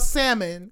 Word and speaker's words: salmon: Like, when people talking about salmon, because it's pronounced salmon, but people will say salmon, and the L is silent.